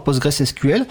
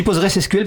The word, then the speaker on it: PostgreSQL: Est-ce que euh, dans les formations actuelles des jeunes euh, ingés, est-ce qu'il y a des formations à PostgreSQL À Postgres, putain, Alors je dis PostgreSQL parce que ça s'écrit SQL à la fin. PostgreSQL Postgres. on peut dire les deux.